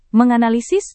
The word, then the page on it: menganalisis: Indonesian